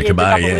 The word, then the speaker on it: Caballé: Yeah, Montserrat, is it Caballé, did you say? – Yeah, Caballé. Double L pronounced Y.